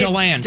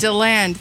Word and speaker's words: DeLand: In Deland, Florida, is that how you say it? – Deland. – Deland, thank you.